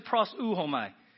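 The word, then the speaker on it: proseuchomai: Okay, so you pronounce it pros uhomai.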